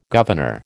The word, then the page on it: governor: American English